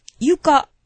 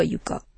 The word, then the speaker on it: 川床: ゆか。ゆかか。あ、読み方がいろいろあるんか。ゆか。鴨川ではゆか。木船。いや、川床と読むのが。